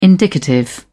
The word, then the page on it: indicative: British English